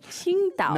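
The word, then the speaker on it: Tsingtao: But on the bottle of you see it, it's spelled T-S-I-N-G. – T-A-O. – Yes, like Qingdao. – Qingdao. Yeah. – So to get the pronunciation right, if you want to sound very cool and you're at your your restaurant somewhere, in Washington or somewhere, and you've been saying Qingdao, no, you can change it and say Qingdao.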